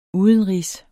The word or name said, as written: udenrigs